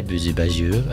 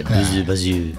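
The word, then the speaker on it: Bouzou Bajou: Started off with our theme music by Buzu Baju. Is that how you reckon I pronounce it? – I say Buzu Baju. Yeah. The more French you say it, the better. Buzu Baju.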